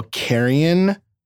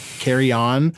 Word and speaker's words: Carrion: Carrion, however we are pronouncing it. Carry On.